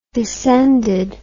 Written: descended

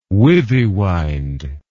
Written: withywind

